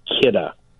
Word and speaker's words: Kidde: KIDDA, K-I-D-D-E, pronounced KIDDA.